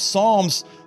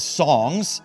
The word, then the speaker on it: Psalms: Psalms, some people mispronounce as songs, which is an understandable thing because Psalms actually are songs.